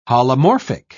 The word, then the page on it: holomorphic: British English